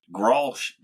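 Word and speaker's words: Grolsch: I've been drinking, I don't know how to pronounce it, Grosslish? – Grosh, Dad.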